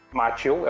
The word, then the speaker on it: Macchio: E aí, tipo, foi tão de um jeito que teve uma hora que eles contrataram os dois atores, tanto o Ralph Macchio, Macchio, eu acho que é assim que se pronuncia, Isso.